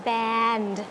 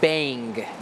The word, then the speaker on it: band: สั้นนิดเดียวเองใช่ค่ะสั้นนิดเดียวเองนะคะส่วนคำต่อไปที่น้องดีไซน์เกือบแล้วนิดเดียวเลยจริงๆคือคำว่า band. – The correct way to spell band is B-A-N-D. If it's spelled with a G, that's going to be pronounced bang. B-A-N-G. It sounds like bang. If it's a D, that's band.